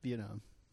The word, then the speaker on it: Vietnam: Vietnam. – Vietnam. – Yeah. – But the accent is on Viet.